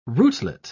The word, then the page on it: rootlet: British English